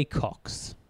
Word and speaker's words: Cocks: And began researching the project with a script to be provided by, I'm going to say this wrong, purely because it's a swear word, but Jay Cox. – Jay Cox. – Which is C-O-C-K-S? – C-O-C-K-S.